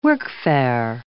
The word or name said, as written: workfare